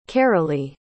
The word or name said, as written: carolie